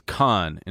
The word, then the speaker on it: Cahn: My last name is Khan, and it's spelled C-A-H-N, and they would say Chan every time.